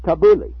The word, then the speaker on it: tabbouleh: Can you pronounce the name of it? – Yeah. It's tabbouleh. – Tabbouleh, exactly.